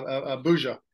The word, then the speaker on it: Abuja: Is a certified athletic administrator, and he's the director of athletics at the American International School of Abuja. Did I say that right? – Yeah, Abuja. – Abuja, and that's in Nigeria.